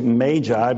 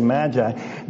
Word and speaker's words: magi: The actual pronunciation in the East is magi, but we in the West say magi.